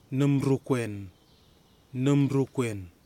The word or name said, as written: numrukwen